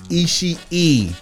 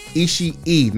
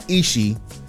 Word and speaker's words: Ishii: Tomohiro Ishii. There are two I's. You pronounce both of them on the end, that is. Three I's in the name. Tomohiro Ishii. Not Ishii. Ishii. – Thank you. I appreciate you. Continue. – WYBA. Anyway. – P-A-F-N.